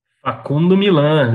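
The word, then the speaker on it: Facundo Milán: começar o fim, o começo do fim, queria que falasse um pouco aí do é, como é que fala? Facundo Milan? É isso que é assim que fala? – Facundo Milan, né, cara? – Facundo Milan. Tá, tá, tá, coisa, tá bonita a pronúncia.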